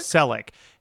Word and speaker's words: Selick: Selick.